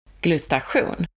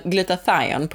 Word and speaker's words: glutation: glutation. Ja, det var det där svenska uttalet av det, men glutathion på engelska i alla fall.